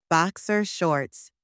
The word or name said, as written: boxer shorts